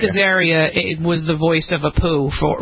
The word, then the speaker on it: Apu: The creator of The Simpsons appears to be suggesting that the blowback over having a white actor voice, is it Apu? Is that how you pronounce Apu.